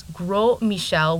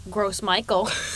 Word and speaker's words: Gros Michel: So its French name was Gros Michel, which I guess translates to Big Mike. – Or you could call it, as I do, Gros Michael. That's not how you say it, but that's what I thought it was.